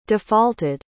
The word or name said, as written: defaulted